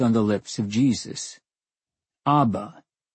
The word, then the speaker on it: Abba: pronounced as Abba.